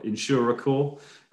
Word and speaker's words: Insurecore: And I said that correctly because I've been saying Insurercore for about for about a month in my head. – You're not the only one. – So I'm glad I did that right,